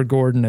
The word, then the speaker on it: Gordon: Yeah, Gordon is how you pronounce that last name.